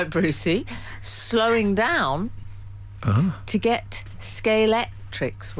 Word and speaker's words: Scalextric: Scalextric. – Yes, it ends with a K. – So it's Scalextric.